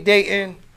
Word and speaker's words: Datin: Yo, my name is Triple D, aka Datin.